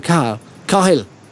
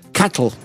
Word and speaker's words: Cathal: It's Cathal, but it's, you don't pronounce the T. So Cahill, Frail, like Anna Frail, like Anna Frail, and then Morris is like Maurice Treacy, but it's Morris Tracy.